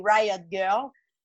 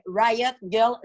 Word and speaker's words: Riot grrrl: Riot Girl, ça s'écrit euh, Riot Girl G-R-R-R-L, donc on peut aussi dire grrr.